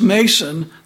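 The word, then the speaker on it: Mnason: There went with us also certain of the disciples of Caesarea, and brought with them one," it's pronounced Mason, the N is silent, "of Cyprus, an old disciple with whom we should lodge."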